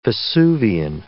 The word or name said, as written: vesuvian